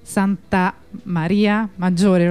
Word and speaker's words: Santa Maria Maggiore: E allora quella al telefono era una compagna della redazione di Radio Onda Rossa dalla piazza in protesta oggi a Roma, piazza Santa Maria Maggiore, l'ho detto bene, sì.